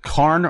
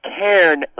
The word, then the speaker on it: Cairn: And before I continue with our topic at hand, and I will eventually get this pronounced correctly, but is it Carn or Cairn? – Yeah, it's Cairn, like air.